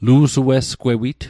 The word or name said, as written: lu'sue'sgwewit